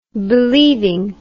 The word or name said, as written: believing